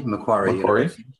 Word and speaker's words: Macquarie: In 2003, Rod completed a PhD at Macquarie. Am I saying that correctly? – Macquarie. – Yeah. Thank you.